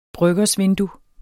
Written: bryggersvindue

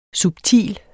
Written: subtil

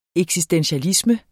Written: eksistentialisme

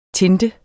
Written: tinte

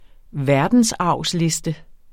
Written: verdensarvsliste

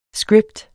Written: script

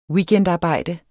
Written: weekendarbejde

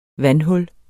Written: vandhul

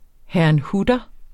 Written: herrnhuter

